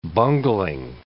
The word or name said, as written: bungling